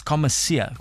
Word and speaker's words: commissaire: Joining us in studio on Pedal and Stride today is the Western Cape Track Cycling Championship Commissar. Commissaire. What's the correct pronunciation? – Commissaire. – Commissaire. That is French, uh, am I correct? – Correct.